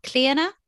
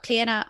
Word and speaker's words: Clíodhna: Clíodhna, and my first name is spelt C L I O D H N A. It's an Irish name. Uh, so that's Clíodhna on Instagram.